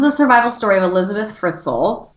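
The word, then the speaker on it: Fritzl: It sounds like a strudel. You're fine. – Really? A strudel? – Yeah. – Oh, Fritzel. – Fritzel.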